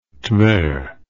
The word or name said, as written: Tver